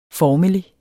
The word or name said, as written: formelig